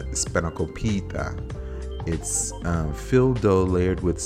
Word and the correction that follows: spanakopita: And hopefully I said that correctly. Spanakopita. Yeah, spanakopita.